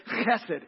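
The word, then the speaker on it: chesed (חסד): The first one that he looked at from the First Testament, from our Old Testament, is the Hebrew word chesed. And you got to do the ha or you're not going to say it right. And if you don't spit on your neighbor, you're not saying it right.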